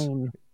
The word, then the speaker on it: Berenstain: It's a question of being the Berenstein or the Berenstain Bears. – Stain. – Is that what it is? – Because I believe it's spelled Stain, but I think everyone just said Steen.